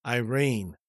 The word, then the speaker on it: Iran: Please note, there is a straight line over the letter A in Iran, making its pronunciation Iran.